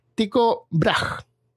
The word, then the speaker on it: Tycho Brahe: Otro astrónomo famoso en ese tiempo que creía haber demostrado la teoría de Copérnico era Tico Brahe, que se escribe tipo Tycho Brahe.